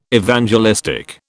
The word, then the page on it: Evangelistic: British English